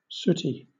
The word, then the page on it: sooty: British English